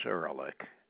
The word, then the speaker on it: Erlick: First name is Albert, and the last name is Ehrlich. That's spelled E-R-L-I-C-K.